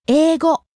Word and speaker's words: 英語: 英語. – The English language. – 英語, 英語, rising on the second syllable, I.